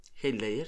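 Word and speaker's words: Heath Ledger: Que son eh, Michelle William y Hate, Hate ¿Cómo se dice? – Hate Layer sí. Em, que ya hemos hablado en este podcast de Hate Ledger porque es un actorazo.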